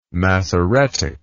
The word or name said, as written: massoretic